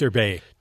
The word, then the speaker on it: Derbez: And you can correct me on the pronunciation of this name, Eugenio Derbez. – How do you Derbez? Derbez.